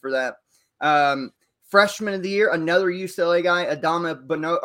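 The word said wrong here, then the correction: Adem Bona: or shit. Adeem Bona. I don't know why I kept saying that wrong.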